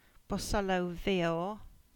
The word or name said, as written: Bosullow Veor